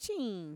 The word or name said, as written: chiin